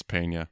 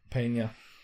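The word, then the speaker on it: Peña: The single mom character is played by Elizabeth Pena. Pena? I probably butchered her name. I apologize. – I think it's Pena. – Pena. Elizabeth Pena.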